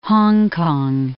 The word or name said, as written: Hong Kong